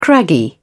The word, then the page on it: craggy: British English